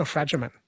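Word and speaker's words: Fragomen: Did I say that right? Fragerman or Fragerman? – Fragerman.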